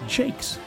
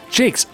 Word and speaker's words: Jacques: Electric Boogaloo says, from Pandacoo says, Richard Jacques' last name is actually pronounced Jakes. – Jakes.